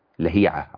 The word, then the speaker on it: لهيعة: تمام، مثلا لهيعة مش لهيعة خالص، لهيعة عبد الله ابن لهيعة.